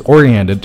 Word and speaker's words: oriented: 这个词啊，发音的时候要注意 orient, 中间还有一个 ent 的发音，不要掉了。Oriented.